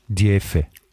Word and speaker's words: DF: Oder wie, äh, wie man in echt sagt oder wie man als Einheimischer sagt, ist Diefe.